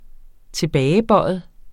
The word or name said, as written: tilbagebøjet